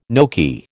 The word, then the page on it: gnocchi: American English